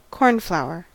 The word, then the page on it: cornflower: American English